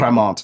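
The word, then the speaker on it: Crémant: Can you spell that for us? – Yeah. C-R-E- I'm seriously asking. – Cremant. C-R-E-M-A-N-T with an accent over the E.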